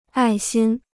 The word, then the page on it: 爱心: ài xīn